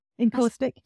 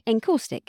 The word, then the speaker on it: encaustic: So we've talked about types of tiles: ceramic, porcelain, glass, stone, and encaustic. Is that how you say it? Yeah. I said this before. – Encaustic. – Encaustic.